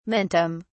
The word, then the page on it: mentum: British English